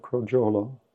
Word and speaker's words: crogiolo: La pronuncia è giusta, crogiolo? Ok.